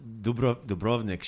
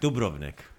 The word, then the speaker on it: Dubrovnik: So if you I noticed that when she pronounced Dubrovnik, she pronounced the accent on the first syllable. So she always Dubrovnik. Said Dubrovnik.